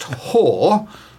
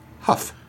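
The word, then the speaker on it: haugh: And according to Mrs F., who is not in any circumstances to be argued with, is pronounced whore rather than, as I tentatively suggested, only to be swiftly corrected, huff.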